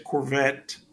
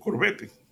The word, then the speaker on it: Corvette: Eh, nosotros, en inglés se dice Corvette, pero en español nosotros decimos Corvette, porque así es como se escribe, ¿no? C-O-R-V-E-T-T-E, -E, Corvette.